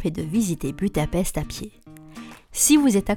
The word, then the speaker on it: Budapest: Il faut savoir que Budapest, qui se prononce Budapest, est composé en deux parties.